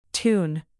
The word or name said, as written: toon